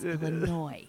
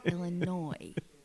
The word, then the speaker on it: Illinois: It's not, and it's a silent S. Illinois. No noise. Illinois.